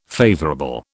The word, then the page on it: favourable: British English